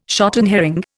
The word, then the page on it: shotten herring: Indian